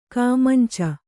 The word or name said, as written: ಕೈಮುಂಚು